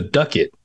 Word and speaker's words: ducat: Yeah, it's a long U because of a singular C before the next vowel, the next, you know, the A. So it looks like ducat instead of ducat.